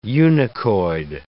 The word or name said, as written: eunuchoid